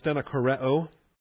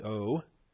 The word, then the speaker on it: stenokoreo: And the verb there is stenokoreo, stenokoreo. That's a short O and then two long O's.